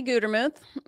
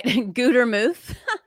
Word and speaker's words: Gutermuth: Gutermuth. Okay. It rhymes with tooth. People will say Guttermouth, which is fine because it's too hard of a last name. So I'll accept any pronunciation.